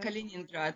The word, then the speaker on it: Kaliningrad: Kaliningrad, yes, Yes.